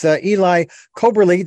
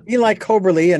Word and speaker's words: Eli Coberly: I want to let you folks know we're talking with Eli Coberly. Do I have your last name pronounced correctly? – Yes. – Yep. Eli Coberly.